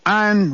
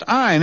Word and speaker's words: Ayn: Jeff informed me it's pronounced Ayn. It's written Ayn, right? – That's correct. It's A-Y-N, and it's pronounced Ayn.